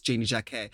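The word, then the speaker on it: Janey Jacké: She DM'd me and said that her name is pronounced Janie Jack A. Like Jack A.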